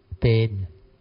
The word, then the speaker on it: paean: P-A-E-A-N. Who know how to pronounce? How to pronounce? E-A-N. Pain. Pain, huh? Oh, okay, thank you.